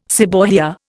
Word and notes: seborrhoea: Indian

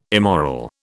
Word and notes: immoral: British English

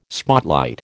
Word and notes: spotlight: American English

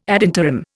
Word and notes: ad interim: Indian

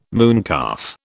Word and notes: mooncalf: British English

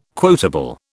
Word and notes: quotable: British English

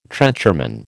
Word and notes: trencherman: American English

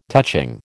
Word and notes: touching: American English

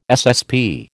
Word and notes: SSP: American English

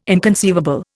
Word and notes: inconceivable: Indian

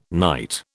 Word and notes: night: British English

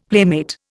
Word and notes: playmate: Indian

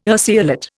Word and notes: urceolate: Indian

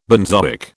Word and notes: benzoic: British English

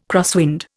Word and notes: crosswind: Indian

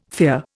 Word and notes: fear: Indian

